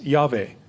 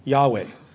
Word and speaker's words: Yahweh: The best that we can hope to pronounce it is Yahweh, Yahweh.